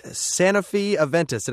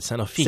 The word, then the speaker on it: Sanofi Aventis: The French pharmaceutical giant Sanofi Aventis. Did I say that right? – You said it right. Sanofi. – Sanofi Aventis. – That's right.